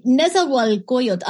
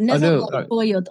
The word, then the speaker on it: Nezahualcóyotl: Okay, Nezahualcóyotl. I'll send it to you. Nezahualcóyotl.